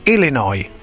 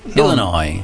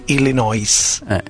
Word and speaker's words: Illinois: Ecco, ripetilo bene il primo, che l'Illinois non si legge la S finale, tutti sbagliano, invece si dice Illinois. – Illinois. – Non Illinois.